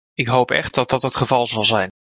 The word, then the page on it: hoop: Dutch